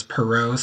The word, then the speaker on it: Parrot: And then my last name is Perot, spelled like parrot, P-A-R-R-O-T, at higher-ed.ohio.gov.